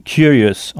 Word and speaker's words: curious: Sachez que beaucoup de mots français qui finissent en E, E-U-X, comme par exemple curieux, finissent en O-U-S en anglais, qu'on prononcera us. Donc curieux se dit curious en anglais. C-U-R-I-O-U-S.